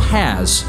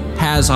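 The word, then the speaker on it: Hass: Today, Haas avocados, often mispronounced as Haas, comprise roughly 95% of the avocado crop in the U.S., making Rudolph into something of a Johnny Avocado seed.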